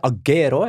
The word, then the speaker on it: Agüero: Når du har det over U-en, da skal du uttale U-en også. – Og i mine første år som La Liga-kommentator, var vi sånn i 07-ish, så sa jeg Agüero, jeg var helt sikker på at det var det, så skjønte jeg at den U-en den skulle uttales likevel.